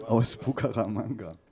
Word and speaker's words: Bucaramanga: Alejandro kommt an aus Bucaramanga.